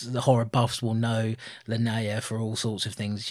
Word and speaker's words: Linnea: Is it Linnea or Linnea? Yeah, I think it is Linnea, and I think I've been saying it wrong for years.